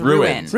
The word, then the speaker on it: ruined: It's two syllables. Ruined.